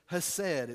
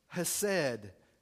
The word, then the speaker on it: hesed: is defined as the word hesed. It's spelled C-H-E-S-E-D. It's an ancient Hebrew word, hesed.